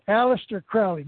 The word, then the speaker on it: Aleister Crowley: I want to read just a couple of quick sentences from a thing called the Book of the Law, written by Aleister Crowley.